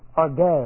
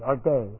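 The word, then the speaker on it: orge (ὀργή): It comes from the root orge, O-R-G long E, orge.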